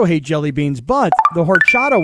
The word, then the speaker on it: horchata: Horchata. Is that how you say that? – Horchata. H is silent.